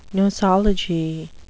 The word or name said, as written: nosology